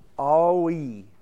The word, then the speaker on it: Ai: And according to the dictionary, that's how you pronounce it. Aoi. But if I say AI, you know what I'm talking about. Okay, so it's the town of AI.